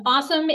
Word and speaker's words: AWSM: Um, awesome is the Center for the Advancement of Women in Science and Medicine, AWSM, which we pronounce Awesome.